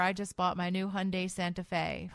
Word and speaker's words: Hyundai: It's Hyundai, I'm just saying. – Hyundai? – I did a commercial, and they told me it's Hyundai. It rhymes with Sunday.